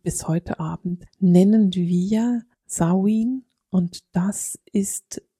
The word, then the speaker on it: Samhain: Der Ausdruck Samhain versteckt sich im Wort Samhain, also das Wort Samhain kennst du vielleicht und das spricht sich eben Samhain aus.